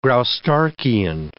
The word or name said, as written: Graustarkian